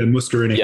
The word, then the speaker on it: muscarinic: But like there's the muscarinic, I think is how you pronounce it. – Yeah, muscarinic.